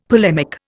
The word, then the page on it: polemic: Indian